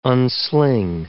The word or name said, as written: unsling